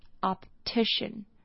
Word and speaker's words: optician: Optician，那它的重音不太一样，这个重音是在 TI 这边，那 O 的发音没有变，那 P 跟 T 一样就是要发出来 Optician Optician。